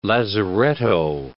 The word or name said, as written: lazaretto